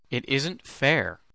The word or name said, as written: it isn't fair